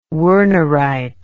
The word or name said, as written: wernerite